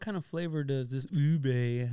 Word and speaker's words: ube: Ube.